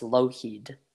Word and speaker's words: Lougheed: And the last name is pronounced Lougheed.